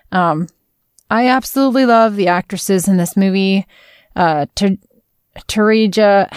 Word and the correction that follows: Taraji: how do you say her name? – Taraji P. Henson.